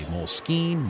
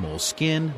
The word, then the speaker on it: Moleskine: I said Moleskine. You can say Moleskine, Moleskine, Moleskine, or whatever you want. The company has no official pronunciation.